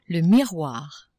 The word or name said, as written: miroir